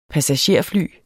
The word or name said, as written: passagerfly